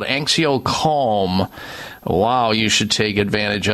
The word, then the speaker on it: AnxioCalm: AnxioCalm is spelled A-N-X-I-O-Calm. Kind of a play on anxiety calm, but it's AnxioCalm.